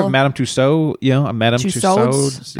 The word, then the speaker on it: Madame Tussauds: And I guess right before his execution, Madame Tussauds, is that how it was pronounced? – Yep.